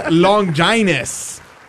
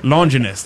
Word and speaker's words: Longinus: It is Longinus, or Longinus, depending on your pronunciation. Some say Longinus, others say Longinus. I've always heard it as Longinus.